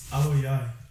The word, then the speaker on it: Aloiai: Aloiai.